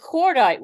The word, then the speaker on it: cordite: And then we get cordite, which is explosive, which rhymes with moonlight, but is an explosive word right there.